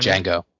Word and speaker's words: Django: It's like Django. The D is silent. – All right. Yes, Django.